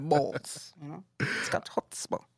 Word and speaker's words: chutzpah: I hope I'm pronouncing this right. Chutzpah. – That's really how it's pronounced. Chutzpah.